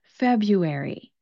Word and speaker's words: February: The nice part about February, even though it has those two R's in the spelling, the first R does not get pronounced, especially if you're speaking with an American accent.